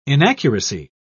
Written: inaccuracy

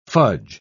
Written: fudge

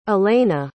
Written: alayna

